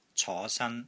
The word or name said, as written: CO5 SAN1